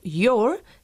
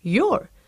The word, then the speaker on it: your: Y observad que el adjetivo posesivo your se pronuncia igual que la forma contraída de you are, es decir, your.